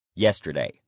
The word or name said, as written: yesterday